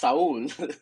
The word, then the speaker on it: Saúl: But basically in the poem I reflect and I do say like, now I do correct people and I tell them like, no, like not Sal or Sal or Sal, Sal, but it's Saul.